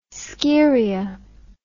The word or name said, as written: Scheria